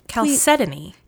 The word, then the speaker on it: chalcedony: I just looked up the pronunciation and we're both doing it wrong. – Okay. – Chalcedony.